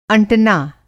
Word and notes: अंटना: आँटना

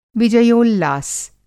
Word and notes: विजयोल्लास: Hindi

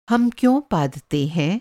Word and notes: पादना: पादना - پادنا

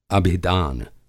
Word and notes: अभिदान: Hindi

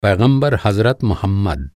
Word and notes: हज़रत: حضرت

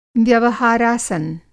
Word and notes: व्यवहारासन: Hindi

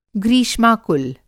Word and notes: ग्रीष्माकुल: Hindi

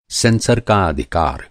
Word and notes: सेंसर: سنسر

